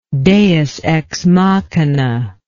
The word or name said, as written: deus ex machina